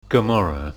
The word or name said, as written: Gomorrah